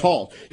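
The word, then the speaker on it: Hauss: Because they say it's Hoss. You know, it's H-A-U-S-S, but it's pronounced like Hoss Cartwright, right? Hoss.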